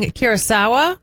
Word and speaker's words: Curaçao: All right, we've got the Ivory Coast facing Curaçao.